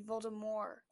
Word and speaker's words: Voldemort: Well, in the movies, you don't pronounce Voldemort right. It's actually supposed to be Voldemort.